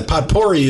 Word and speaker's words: potpourri: It's not spelled the way it sounds, but... – Yes, potpourri. – Yeah, potpourri is not... Potpourri is the way it's spelled.